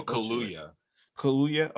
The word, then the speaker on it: Kaluuya: Daniel Kaluuya.